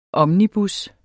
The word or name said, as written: omnibus